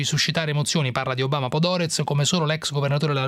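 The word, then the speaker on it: Podhoretz: Che il leader dei cosiddetti neocon americani, che si chiama Norman Podoretz, così è il, si pronuncia? – Sì, esattamente così.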